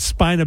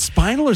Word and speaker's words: spina bifida: I make sure, because I go, is it spinal or spina? – Spina.